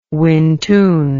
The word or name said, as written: wintun